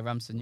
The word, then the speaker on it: Ramson: Yeah, Ramson.